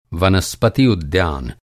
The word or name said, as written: उद्यान